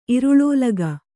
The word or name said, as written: ಇರುಳೋಲಗ